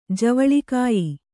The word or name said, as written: ಜೇವಳಿಗೆಯ್